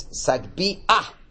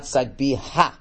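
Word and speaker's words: sagbia: Okay, not pronounced ha, but pronounced ah. Okay, it's a patach genuva, a stolen patach. So everybody mispronounces it. In Hallel, they mispronounce it. It's sagbi ah. Not sagbi ha, all right.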